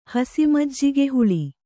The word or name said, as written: ಹಸಿಮಜ್ಜಿಗೆಹುಳಿ